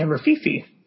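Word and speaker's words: Rififi: Yeah, Rafifi.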